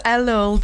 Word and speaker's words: Elul: Is it Elul? How is it pronounced? The Jewish month of... – Very good. Prepared. We're starting the month of Elul this week, which is the last month before the Jewish New Year.